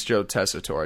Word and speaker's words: Tessitore: But also, I said his name wrong. It's Joe Tessitore.